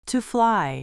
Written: to fly

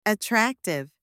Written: attractive

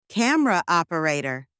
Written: camera operator

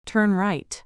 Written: turn right

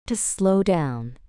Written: to slow down

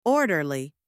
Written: orderly

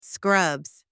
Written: scrubs

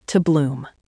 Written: to bloom

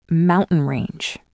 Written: mountain range

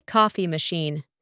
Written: coffee machine

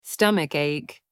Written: stomach ache

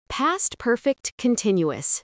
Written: past perfect continuous

